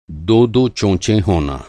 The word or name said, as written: चोंच